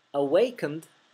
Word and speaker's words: awakened: Por ahora les explico que no se pronuncia awakened, sino que se pronuncia awakened. La E es muda.